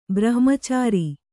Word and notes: ಬ್ರಹ್ಮಚಾರಿ: brahmacāri

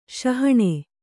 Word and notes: ಶಹಣೆ: śahaṇe

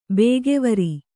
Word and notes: ಬಗೆವರ್: Kannada

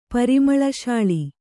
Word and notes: ಪರಿಮಳಶಾಳಿ: Kannada

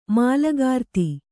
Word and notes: ಮೂಲಕೃತಿ: mūla křti

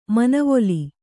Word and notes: ಮನವೊಲಿ: manavoli

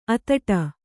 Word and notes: ಅತ್ತಿಡು: attiḍu

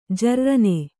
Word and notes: ಜರ್ರನೆ: jarrane

